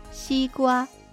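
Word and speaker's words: シーグヮー: 夏もだいぶ後半になってきましたけど、やはり夏の食べ物といえばこれ、シーグワ、シーグワ。はい、西のウリと書いてシーグワ、スイカですね。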